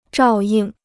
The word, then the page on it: 照应: zhào ying